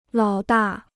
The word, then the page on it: 老大: lǎo dà